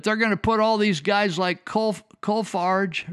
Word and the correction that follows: Kolfage: or Kolfage. I can't say his name. Kolfage, Kolfage, K O L F A G E.